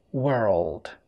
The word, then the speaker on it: world: تلاحظ ان انا ما بقولش word، لا مفيش ضم في البق هنا word word والكلمة التانية اللي فيها L نطقها كده world world world.